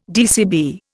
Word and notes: DCB: Indian

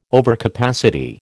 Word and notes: overcapacity: American English

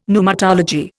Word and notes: pneumatology: Indian

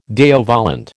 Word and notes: Deo volente: American English

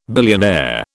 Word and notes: billionaire: British English

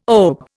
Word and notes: OBE: Indian

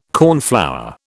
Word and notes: cornflower: British English

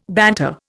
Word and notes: banter: Indian

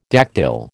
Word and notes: dactyl: American English

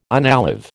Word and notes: unalive: American English

